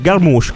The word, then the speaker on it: Jarmusch: Ah, è grandissimo, Jim Jarmusch, Garmusch comunque.